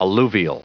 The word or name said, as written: alluvial